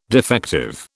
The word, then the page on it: defective: British English